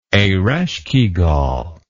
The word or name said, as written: ereshkigal